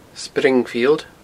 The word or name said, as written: springfield